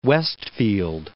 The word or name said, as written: Westfield